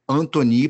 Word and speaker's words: Antoni: É, se escreve com C, mas se fala Vinícius Anthony, porque a, a, mãe dele botou esse nome, a mãe tem raízes italianas, botou esse nome, então.